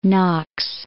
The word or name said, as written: Knox